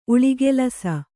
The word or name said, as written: ಉಳಿಗೆಲಸ